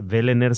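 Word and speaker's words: Wehlener: Está, Vélener se llama el pueblo. Vélener. Porque la W se pronuncia como, como V.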